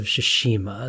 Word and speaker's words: Tsushima: You mentioned that you've been playing Ghosts of Tsushima. Is that pronounced? – Yes. – Phonetically? – Yes. Yeah, you actually got it.